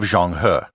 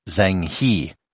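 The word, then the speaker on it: Zheng He: If you read the title of this episode and you speak English, you might pronounce it as Zhanghe. I'm trying to stay true to the Chinese pronunciation as much as I can.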